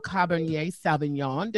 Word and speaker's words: Cabernet Sauvignon: I have a Cabernet Sauvignon.